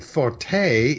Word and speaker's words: forte: And forte is Italian. And that is, you know, a loud sound in music is a forte. – Sure, yeah. – In which case it's accented on the first syllable.